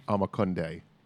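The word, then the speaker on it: Omokunde: That was Leon Young's former seat in Milwaukee, and Supreme Moore, Akamunde is, am I mispronouncing it? – Amakunde.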